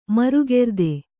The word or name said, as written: ಮರುಗೆರ್ದೆ